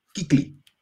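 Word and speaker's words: Kuechly: Y me acuerdo que el día del draft dijeron, a ver, la guía de pronunciación oficial dice Kikli.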